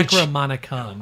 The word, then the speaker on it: Necronomicon: Is it Necronomicon? – Yeah. – Because they had to probably... – Necronomicon.